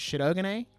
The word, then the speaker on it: Shirogane: If you want to say it how they say it in the dub, it's Shirogane. – Shirogane. – They kind of do the R more like a D.